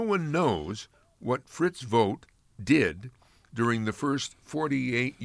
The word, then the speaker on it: Vogt: Fritz Vogt, which is spelled V O G T, Fritz Vogt, slept in barns and relieved the pain of rheumatism with alcohol.